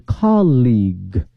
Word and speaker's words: colleague: 大学呢是 college C-O-L-L-E-G-E，而这个呢是 C-O-L-L-E-A-G-U-E，发音不一样哈。我们说同事呢是 colleague、 colleague，而大学呢是 college、所以两个词呢同学们一定要仔细看了哈，能够分得清哈。